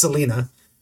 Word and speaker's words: Celaena: I believe it's pronounced Selena, C-E-L-A-E-N-A.